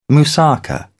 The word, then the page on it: moussaka: British English